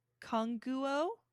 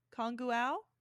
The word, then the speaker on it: Congou: The next one is pronounced Konguo. Konguao. It's spelled C-O-N-G-O-U.